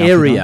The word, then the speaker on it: Aarya: Your restaurant, it's spelled A-A-R-Y-A. How would I pronounce that? – There's an easier way to pronounce that. You speak a bit of Afrikaans, do you? – I do. – What is the mathematical result of length times breadth? – Okay, so that's in area.